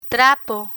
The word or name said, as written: trapo